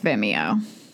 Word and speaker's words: Vimeo: I'm never entirely certain how that I is supposed to be pronounced. – I'm pretty sure it's Vimeo.